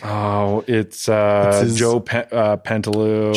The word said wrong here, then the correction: Pantoliano: Joe Pesci. – Nope. – Joe Pantaloon? – Pantoliano? – Okay. – Pantoliano. – Sounds right.